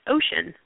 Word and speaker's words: Oshon: And just to share, my last name is actually pronounced Ocean, just like the ocean.